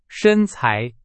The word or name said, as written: 身材